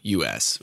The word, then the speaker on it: Cretaceous: Crustaceous? – I think it's this one right here. – Oh. Cretaceous, right? – Yeah.